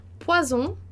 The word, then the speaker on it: poison: Isso é muito importante porque a gente tem palavras, por exemplo, como poison. Poison, a gente vai falar com som de Z porque tem um S que é veneno, tá?